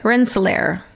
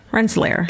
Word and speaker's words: Rensselaer: Rensselaer. Rensselaer. – Spell it. – R-E-N-S-S-E-L-A-E-R.